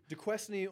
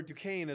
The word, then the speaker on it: Duquesne: DeQuesney or Duquesne, as it's more properly pronounced.